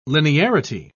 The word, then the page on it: linearity: British English